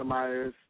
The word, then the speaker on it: Myers: We have, you can look me up on Facebook, The Arthur Myers II, or you can...